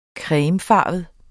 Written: cremefarvet

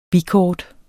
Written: bikort

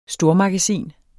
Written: STORMAGASINER

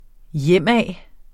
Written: hjemad

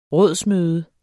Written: rådsmøde

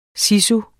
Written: sisu

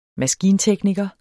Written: maskintekniker

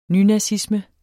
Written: nynazisme

